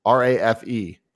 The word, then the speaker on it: Rafe: The ultimate goal for me is I forget to say how to say his name is Raph from Uncharted 4. – Rafe. – It might be Rafe. – Yeah. R-A-F-E.